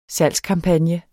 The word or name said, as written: salgskampagne